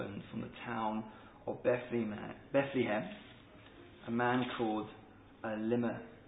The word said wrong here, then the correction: Elimelech: Oh, I said it right in my head. See, when I'm putting the pressure. Uh, Elimelech.